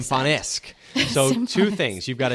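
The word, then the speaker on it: Symfonisk: Symphonisk. – Let me see it. – Symphonisk. – It's Symphonisque.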